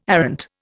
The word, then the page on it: arrant: Indian